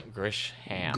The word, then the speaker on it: Grisham: By John Grisham. – Grisham. You just say it smoothly.